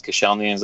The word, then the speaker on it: Koscielny: Is Koscielny, am I saying that right? – Yeah, that sounded good.